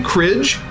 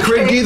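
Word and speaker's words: Kridge: Cridgy, that's K-R-I-D-G-E. It is pronounced Cridgy. It is not that thing I said before. – Cridge? – No, it is not Cridge. It is Cridgy.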